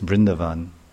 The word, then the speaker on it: Vrindavan: And how Radha and Krishna are chanting the name of Vrindavan. How they chant the name Vrindavan. Actually, it's Vrindavan. The A is not long. Vrindavan. Vrindavan. It's short. A and Van is short A.